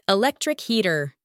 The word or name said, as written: electric heater